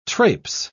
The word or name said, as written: traipse